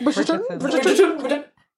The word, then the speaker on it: Bridgerton: Bridgerton? Mm-hmm. Whatever. – Yeah. – Yes. – Bridgerton.